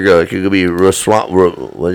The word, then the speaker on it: Ramaswamy: Yeah, it's like if you look at the pronunciation on that, it's Ramaswamy. – Ramaswamy.